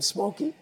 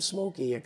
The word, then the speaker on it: Smokie: And so I got the nickname Smokey. – My dad spelled it with a Y, and I spell it I-E, S-M-O-K-I-E. – Mm-hmm. So that's where the nickname Smokey comes from.